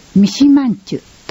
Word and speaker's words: 三島人: 青少年指導者養成研修、三島人と書いて三島んちゅと読みます。